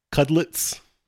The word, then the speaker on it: Cudlitz: How do you pronounce his last name? Cudlitz? – Cudlitz. – Cudlitz. – Cudlitz. Cudlitz. Yeah.